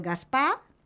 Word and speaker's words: Gaspar: El Gaspar, igual. – Sense pronunciar la R, Sense pronunciar la R.